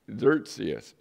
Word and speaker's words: Xerxes: The king that is called Ahasuerus here is also known as King Xerxes. It's spelled X-E-R-X-E-S, but it's pronounced like if you would spell it phonetically, Z-E-R-C-E-S. So Xerxes, but it's spelled X-E-R-X-E-S.